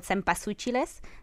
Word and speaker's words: cempasúchiles: So these are altars that you create where you put photos and you put flowers, marigolds called cempasúchiles. That's how you say it in Spanish.